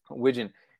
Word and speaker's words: wigeon: He's asking if you get a lot of I'm not sure if I'm saying this right. – Wigan and Mike. Yeah, Wigan. My call number. – Wigan. – He calls Wigeon. What's that? – Wigeon.